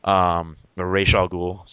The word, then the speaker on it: Ra's al Ghul: made him they created Ra's al Ghul. Ra's al Ghul, sorry. – That's right. – I corrected myself. I'm like, oh crap, I said it wrong.